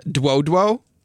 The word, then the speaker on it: Dlodlo: And I'm going to pronounce this, or try to pronounce this correctly, Duo Duo. And you spell the name D-L-O-D-L-O. Apparently it's a phonetic.